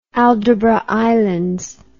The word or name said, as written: aldabra islands